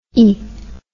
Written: ey

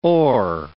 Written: o'er

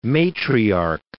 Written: matriarch

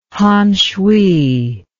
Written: Hanshui

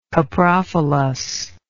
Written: coprophilous

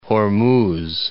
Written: Hormuz

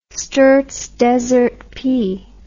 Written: sturts desert pea

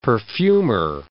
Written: perfumer

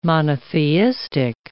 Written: monotheistic

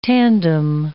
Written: tandem

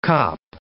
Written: cop

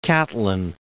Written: Catlin

